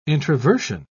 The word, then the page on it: introversion: British English